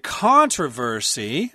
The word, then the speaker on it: controversy: Controversy. Okay. That's the American pronunciation. In England, they will be saying controversy.